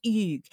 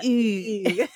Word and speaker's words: Hugues: No, it's Hugues. No, yeah. Hugues.